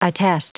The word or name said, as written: attest